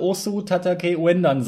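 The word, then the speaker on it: Osu! Tatakae! Ouendan: Ich muss echt ganz kurz überlegen, aber es müsste Osu Tatake Uendan sein, wenn ich das halbwegs richtig ausgesprochen habe. Du kannst es nochmal sagen. Ganz genau so ist es.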